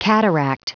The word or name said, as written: cataract